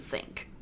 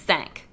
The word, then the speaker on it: cinq: It is the mispronounced French word for five. Not sink like the kitchen sink. I just want to be clear about It's spelled C-I-N-Q, which is supposed to be said.